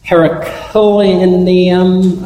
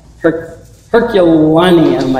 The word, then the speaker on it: Herculaneum: Gary DeMar notes that Pompeii and Herculaneum, I guess, Herculaneum, I think is how it may be pronounced, but it's almost like Hercules.